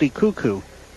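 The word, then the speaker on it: Dekoek: She spells her name D-E-K-O-E-K, K-O-E-K, but it is pronounced DeCucu. It's a very Dutch-derived name.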